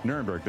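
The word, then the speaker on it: Nirenberg: Mayor Ron Nuremberg, am I saying it right? – Nuremberg, that's correct.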